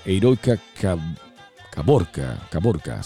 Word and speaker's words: Heroica Caborca: Ya llegando a los últimos segundos de este programa del día de hoy, saludos a Vázquez César, a la heroica Carboca, o Caborcar, no sé cómo se pronuncia. Oh, es una ciudad, Heroica Caborca, sí.